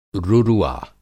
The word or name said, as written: रुरुआ